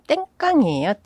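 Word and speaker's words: 땡깡이에요 (癲癇): 이제 그게 일본 발음으로 하면은 땡깡이에요.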